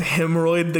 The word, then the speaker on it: Hymnroyd: Also, I just want to say, because I'm sad you immediately killed him, but his name was Hemroid the Goblin, and it's spelled HYMNROYD.